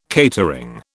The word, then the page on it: catering: British English